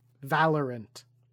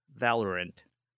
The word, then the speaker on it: Valorant: One day you're going to learn how to say it. – How do I say it? – Valorant. – Valiant. Anyways, no, so what? – It's Valiant, right? – No, there's an R. – Valorant. – Yes.